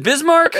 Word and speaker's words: Bismarck: All right, let's go to uh, Sarah in Bismarck, North Dakota. What's up, Sarah? How are you? Hey, I think I just mispronounced that. Is it Bismarck? Uh, yes, it is.